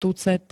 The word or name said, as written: tucet